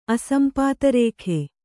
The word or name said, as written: ಅಸಂಪಾತರೇಖೆ